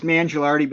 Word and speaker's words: Mangialardi: And it's Mangialardi, but that's okay.